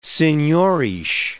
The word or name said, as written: senhores